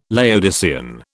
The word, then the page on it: Laodicean: British English